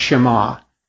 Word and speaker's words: Shema: That's because the first word of the sentence in Hebrew is pronounced Shema.